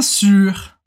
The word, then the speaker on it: sûr: Bien sûr. Bien sûr. E atenção aqui para a pronúncia do sûr, que aqui a gente tem o som do biquinho do francês, que é uma mistura de I, com, U.